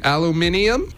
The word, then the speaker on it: aluminium: Have you tried doing it the English way? Aluminium? – See, you said it wrong. – No, but that's how the English say it. They say aluminium.